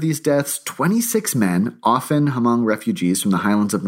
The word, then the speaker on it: Hmong: I think it's Hmong. – Hmong, yes. Oh, you're right. You're right. It is Hmong.